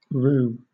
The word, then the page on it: rue: British English